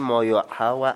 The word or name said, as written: muyuqhawa